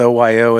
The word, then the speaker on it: OYO: how do you pronounce it? Is it just OYO? – OYO. – Okay. Yeah, yeah. I didn't know if it was OYO or.